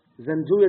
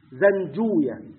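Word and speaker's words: زنجويه: زنجوية دي اسم فارسي كما قلنا، ينطق بهذه الطريقة عند المحدثين، زنجوية كما نقول راهوية اللي هي المقطع الأخير أوية، ده اسم فارسي.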